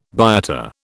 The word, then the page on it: biota: British English